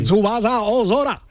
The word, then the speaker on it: Tsubasa Ozora: Pero vamos a pronunciarlo bien. Sí. Tsubasa Ozora.